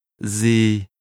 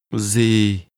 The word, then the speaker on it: gì: Gì? Chị muốn gì? – The word for what is pronounced with a low, falling tone. Say it again. – Gì?